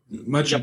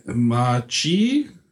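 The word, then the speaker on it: Machi Koro: Also Machikoro. Japaner neigen dazu, das, die zweite Silbe zu betonen. – Machikoro? – Ja, und jetzt noch zusammenfassen. Machikoro.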